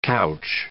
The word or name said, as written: couch